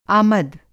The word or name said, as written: आमद